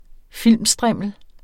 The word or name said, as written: filmstrimmel